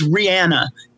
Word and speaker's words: Rihanna: I'm like, 100 million people are mispronouncing Rihanna's name. Yeah. This is not how she pronounces it herself. She says Rihanna and we all say it wrong.